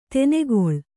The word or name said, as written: ದಿನಗಳಿ